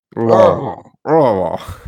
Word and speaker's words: Louisville: I have been corrected many times that it is essentially just one consistent R, and that's how you pronounce Louisville. – Yeah, it's just one big low R sound.